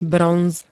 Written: bronz

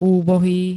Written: úbohý

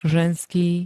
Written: ženský

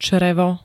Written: črevo

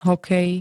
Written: hokej

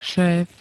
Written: šéf

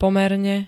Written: pomerne

